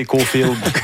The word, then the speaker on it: Caulfield: Caulfield. Ah ouais, c'est vous qui le prononcez comme ça. Vous et Guy Carbonneau prononcez Caulfield.